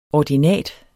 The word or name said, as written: ordinat